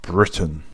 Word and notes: Britain: British English; /ˈbɹɪ.tən/

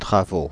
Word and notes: travaux: [tʁa.vo]; /tʁa.vo/

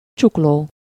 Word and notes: wrist: [ɹɪst]; American English

